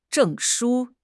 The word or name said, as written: 证书